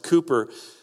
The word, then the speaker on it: Cowper: His name's pronounced Cooper, from the 1800s, a great Christian leader that made a tremendous mark on his day and age.